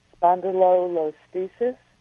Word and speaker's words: spondylolisthesis: And they have, doctors have determined that I have, and I hope I pronounced this correctly, spondylolisthesis. – Yes, you did. Perfect.